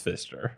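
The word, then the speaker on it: Pfister: And it's spelled with a PF, so it looks like Pfizer, but it's Fister.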